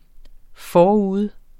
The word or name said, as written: forude